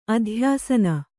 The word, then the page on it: ಅಧ್ಯಾಸನ: adhyāsana